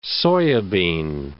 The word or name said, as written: soya bean